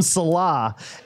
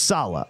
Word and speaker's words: Saleh: He used to call him Salah, and it's Salah.